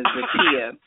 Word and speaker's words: Zakiya: Yeah, just really quickly, the correct pronunciation of my first name is Zakiah.